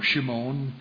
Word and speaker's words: Shimon: Now when Jesus looked at Peter, he said, "You are Simon," or Shimon, Simeon, as it would have been pronounced in Hebrew or Aramaic, "the son of Jonah.